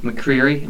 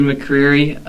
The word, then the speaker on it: McCreery: We're here with David McCreary. Am I saying that right? – That is correct. – All right. David McCreary of Coldwell Banker.